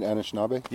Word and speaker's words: Anishinaabe: Am I saying it right, Anishinaabe? – Yep. – Okay. – Yep. Where I come from, often we drop the A at the front. We just say Anishinaabe, but yeah.